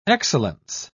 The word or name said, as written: excellence